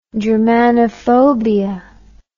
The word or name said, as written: germanophobia